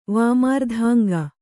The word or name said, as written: ವಾಮಾರ್ಧಾಂಗ